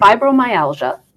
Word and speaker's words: fibromyalgia: Fibromyalgia.